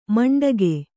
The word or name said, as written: ಮಂಡಗೆ